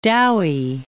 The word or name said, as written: Dowie